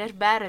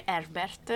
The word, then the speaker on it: Herbert: Allora, Dune di Villeneuve riprende un romanzo degli anni 60 di Herbert, pronuncia corretta, giusto? – Ma sì, a me piace dire Herbert, si può dire Herbert. – Herbert. – Scegliete voi. – Esatto, ambientata in, diciamo, in questo pianeta.